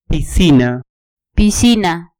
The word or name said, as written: piscina